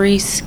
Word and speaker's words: Reese: Kimara Reese, K-E-Y-M-A-I-R-A-R-E-E-S-E.